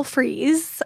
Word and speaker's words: Freas: It's spelled F-R-E-A-S. – Okay. – Which I kind of wish it was spelled like the like to freeze.